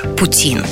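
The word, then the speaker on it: poutine: Еще ударение на французский манер – Путин.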